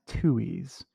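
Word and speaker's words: Tuohy: The attorney for the, I believe it's pronounced Tui's, say that Oher's claims were,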